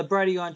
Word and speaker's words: Brighteon: I know you also have, is it Brighteon? Is that how you pronounce? – Yeah, the Brighteon channel, yeah.